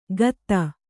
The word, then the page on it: ಗತ್ತ: gatta